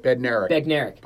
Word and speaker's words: Bednarik: So I have, you know, Reggie White, Chuck Bednarik, Jack Tatum. – Bednarik. – How do you pronounce it? – Bednarik.